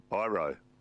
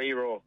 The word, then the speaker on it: Iro: Oh, Iro. – Well, it's yeah. Well, where's he from? – People do say Iro. I go E-Raw. That's how it's supposed to be.